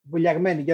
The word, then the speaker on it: Vouliagmeni: Vouliagmeni, yeah, that's very correct. Well, you get it. You say it right, because you're half Greek, because it's a little bit difficult for other people to pronounce.